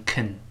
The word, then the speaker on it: can: 元音弱化就是原来这个 a 变成 a a 这个声音。所以能其实一般读成 can can I can do it I can do it，这是 can 的声音。